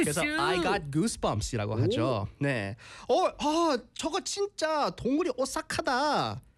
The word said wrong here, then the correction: 등골: 등골이 오싹하다.